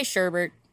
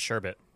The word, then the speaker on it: sherbet: Yeah, no one says sherbet, but that's what it is. Sherbet. – Why is the R silent? – There is no R. – But, so you just pronounce an R that's not there? – Yeah, literally. – Oh, okay. – Yeah, I didn't know that there wasn't an R. – No, there's no R in sherbet.